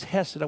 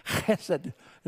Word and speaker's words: chesed: The Hebrew word there is chesed. I want you to learn a Jewish word, a Hebrew word, chesed, C-H-E-S-T. Chesed is how you say it.